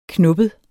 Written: knoppet

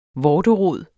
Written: vorterod

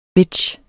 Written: bitch

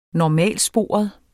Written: normalsporet

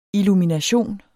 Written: illumination

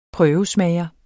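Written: PRØVESMAGER

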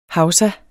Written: hausa

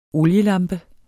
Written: OLIELAMPER

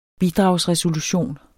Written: bidragsresolution

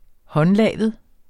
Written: håndlavet

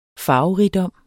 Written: farverigdom

